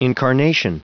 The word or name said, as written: incarnation